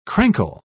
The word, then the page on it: crankle: British English